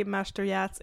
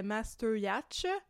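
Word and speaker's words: Mashteuiatsh: Oui, la prononciation de Google est celle que j'avais utilisée dans l'épisode qui était le contraire, qui était Mashteuiatz. – Yep. – Bon, et c'était pas ça.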